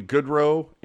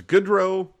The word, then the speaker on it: Goodrow: or Barkley Goodrow and Johnny Gaudreau. Like, there is a difference between the pronunciation, is all I'm saying. There's Goodrow and there's Gaudreau.